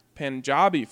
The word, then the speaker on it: Punjab: You guys should definitely try Punjabi food.